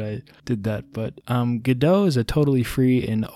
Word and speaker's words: Godot: Godot, I, I used to call Godot Godot because I never heard anyone say it out loud, and it's spelt like Godot.